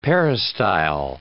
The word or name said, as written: peristyle